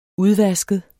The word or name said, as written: udvasket